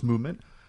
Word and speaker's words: MVMT: Movement Watches, spelled MVMT but pronounced Movement, was founded on the belief that style shouldn't break the bank.